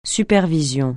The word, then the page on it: supervision: francais